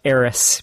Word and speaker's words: Aerith: All right, next up, we have 13-seeded Ellis versus number 3-seeded Auron from FF10 versus number 14, fuck you, GameFAQs, it's pronounced Eris because we live in the United States, Gainsborough from FF7. Like, who translated that to A-E-R-I-T-H?